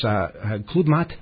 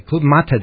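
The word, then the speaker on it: Club-Mate: It's Club Mate Day. Did I say that right? – No. – How should I have said that? – Mate. – Club Mate Day.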